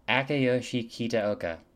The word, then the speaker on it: Akiyoshi Kitaoka: Akiyoshi Kitaoka. – Akiyoshi Kitaoka, what? – Akiyoshi Kitaoka. – Kitaoka. That's not that, you did pretty good.